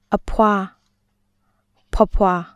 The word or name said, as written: (သူတစ်ပါးရဲ့)အဖွား/(သူတစ်ပါးရဲ့)ဖွားဖွား